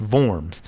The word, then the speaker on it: Worms: It's spelled Worms, but it's Worms. And the term was diet, D-I-E-T, which doesn't mean diet.